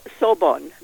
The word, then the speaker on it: Sorbonne: Sorbonne.